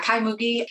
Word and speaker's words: Kai Mugi: Yeah, I don't want to end without talking a little bit about Kai Mugi. Is that how you pronounce his name? – Kai Mugi. He's German. Yeah.